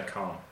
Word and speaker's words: can't: because very often native speakers do not pronounce clearly the T at the end of can't. They wouldn't say, I can't speak Spanish. They would say, I can't.